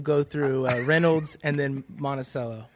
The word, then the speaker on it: Monticello: Monticello? – Yeah, we pronounce it wrong there. Monticello.